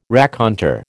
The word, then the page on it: raconteur: American English